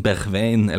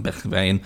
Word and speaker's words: Bergwijn: du kan uttale det sånn skikkelig på nederlandsk og da blir det vel en sånn litt sånn rar lyd som jeg ikke har I språket vårt det blir sånn Bergwijn eller noe